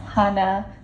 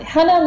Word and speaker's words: Hana: And the correct pronunciation is Hana. So you were close. – Hana with sort of a soft A. – Exactly, exactly. Hana like honey. – Ooh. – Sweet like honey.